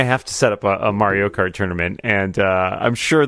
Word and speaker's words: Mario: Mario.